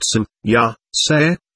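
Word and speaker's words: Semjase: She stated that her name was Samjace, pronounced Sim, Ya, Se, and that she originated from the region of the Pleiades.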